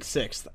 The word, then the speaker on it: sixth: You have an issue with pronouncing sixth. I've noticed that. – Okay, David called that out, too. It's sixth.